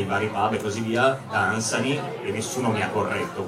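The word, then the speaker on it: Dunsany: Intanto Lord Danzani, la pronuncia sembra sia questa, insomma, almeno è il suo pronipote attuale, il 21esimo barone Danzani, pronuncia Danzani, quindi chi siamo noi per dire che non è così.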